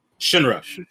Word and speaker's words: Shinra: Yo, I'm going to probably pronounce this guy's name incorrectly, but is it Shirani? – Yeah. The main character? – Yeah. – Shinra. – Shinra. Yeah. Shinra.